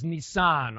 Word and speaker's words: Nisan: And so we got under Passover, the crucifixion of Jesus, and Nisan, or some pronounce it Nisan, but I'm told that's the English way of saying it, that the Hebrew word is Nisan, all right?